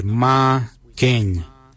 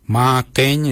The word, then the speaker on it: marrgeny: The number 6 we say Ma Keng. Ma Keng is spelled M-A-R-R-G-E-N-Y.